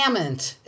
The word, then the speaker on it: amn't: I amn't. It's pronounced amn't, Ross, because I use it.